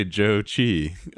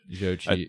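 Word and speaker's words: Zhou Qi: I think it's something like, I remember reading an article and they just said, say Joe Chi. I know, but Joe Chi.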